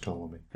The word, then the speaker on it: Ptolemy: It's just Ptolemy. – Is it silent P? – It's silent P.